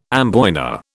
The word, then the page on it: amboyna: British English